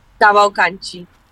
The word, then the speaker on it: Cavalcante: And actually Jacqueline Cavalcante, is that how you say your last name? – Cavalcante. in Portuguese.